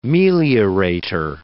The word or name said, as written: meliorator